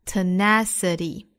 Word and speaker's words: tenacity: Merely, 单词内含字母 r, 美音对应 r 的发音 ,merely 后面 tenacity, 重音带倒数第三音节 ,da da da da, tenacity.